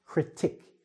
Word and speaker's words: critic: Also, it's a short sound, critic, not a long vowel sound, okay?